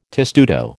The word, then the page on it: testudo: American English